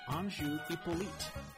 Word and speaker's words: Anju Hyppolite: This is a Wednesday, and it's actually unusual that we're doing it on a Wednesday, but we have a wonderful, wonderful guest, Anju Hyppolite.